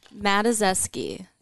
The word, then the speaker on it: Matuszewski: Matuszewski. Can you spell that? – M-A-D? – No. – A-C-S-K-I-N. – M-A-T.